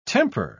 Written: temper

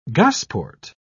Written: gosport

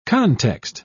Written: context

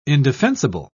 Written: indefensible

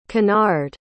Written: kennard

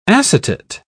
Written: acetate

